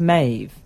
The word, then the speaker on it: Meadhbh: And we've established how to pronounce M-E-A-D-H-B-H. Notice I say H, not H. It's pronounced Maeve. The B-H is a Gaelic V. – M-A-E-V-E.